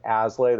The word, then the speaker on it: Aslay: If not, they can go to, my name is Jonathon Aslay.